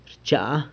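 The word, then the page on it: rchaa: San Lucas Quiaviní Zapotec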